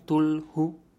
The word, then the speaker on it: Cthulhu: Tulu, que se escribe C-T-H-U-L-H-U, Tulhu,